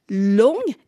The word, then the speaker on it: loong: La bonne traduction, selon eux, aurait dû être « long », L-O-O-N-G, qui est proche de sa prononciation en chinois.